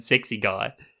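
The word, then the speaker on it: Secky Guy: The second review on the 17th of December 2020 was from Sexy Guy. That is S-E-C-K-Y guy. So, but okay. I would pronounce it Sexy Guy.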